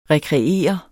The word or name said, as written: rekreere